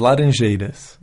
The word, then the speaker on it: laranjeira: Aunque eso sí, está este pajarito, que también le dicen sabía laranjeira, no sé si está bien pronunciado, pero por lo menos así es conocido por allá en Brasil. – las eh, laranjeiras. Laranjeiras. Laranjeiras. Así mismo.